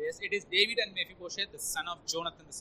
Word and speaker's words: Mephibosheth: It's Mephibosheth and David. Sorry, I mean we're pronouncing it wrong. Mephibosheth.